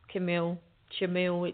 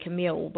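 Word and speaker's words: Chamuel: Shamuel, it depends how you pronounce it into where you are. I say Camille, but I think in Shamuel is what you say in the States.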